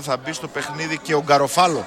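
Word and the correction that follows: Garofalo: Γκαρόφαλο. – Γκαρόφαλο. – Για να είμαστε σωστοί.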